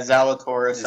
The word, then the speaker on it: Zalatoris: If I do, it's Zalatoris, I believe. – Yeah, Zalatoris.